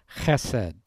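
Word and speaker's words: chesed (חסד): The Hebrew word is the word chesed, C-H-E-S-E-D-H, for those of you that are writing it down, chesed. You sort of have to make a rude sound to be able to pronounce it properly.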